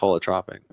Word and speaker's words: holotropic: Did I say it right? Holotrophic or holotropic? – I think it's holotropic.